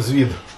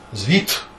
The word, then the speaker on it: звіт: То ти неправильно вимовляєш. Ти оглушаєш ДУТ на конфі. – Я спішаюся, говорю, я з тобою цілком згодний, що треба говорити з банком. – Звіт. – Звіт.